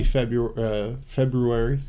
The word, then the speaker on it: February: Yeah, so we sent those out again early February. Gotta keep mispronouncing that, the name of that month. There is a second R in there.